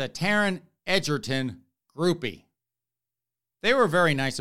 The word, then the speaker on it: Egerton: Apparently, I mispronounced Taron Egerton's last name. It is, allegedly, supposedly, possibly, maybe, pronounced Taron Egerton.